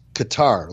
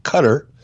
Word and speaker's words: Qatar: And social media, I've been pronouncing the country of Qatar as Qatar, like guitar, kind of like. – Mm-hmm. Yeah, that's how they say it on 90 Day Fiance, Qatar. – Yeah, well, evidently I'm wrong. I've been corrected. And the correct pronunciation of the country is Qatar.